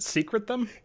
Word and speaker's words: secrete: Isn't it secret them? – Yes. – Why would you say secret them? You say secrete them.